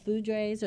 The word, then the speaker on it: foudres: These, I guess, Edouard, do you pronounce it foudres? They're called foudres, or they're big oak barrels.